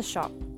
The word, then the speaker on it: scop: I act as a shop, an old English word pronounced kind of like shop, but pronounced S-C-O-P. And I realized I just said shop twice, but it's kind of like you're a little British, maybe.